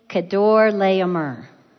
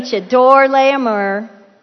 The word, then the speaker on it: Kedorlaomer: But these Jordanian kings in the area decide they're going to rebel after 13 years of being subject to, I'm going to say his name right, King Kedorlaomer. It looks like King Cheddar something. I was thinking about King Cheddar cheese. Okay, King Kedorlaomer and three Easter kings aligned with him.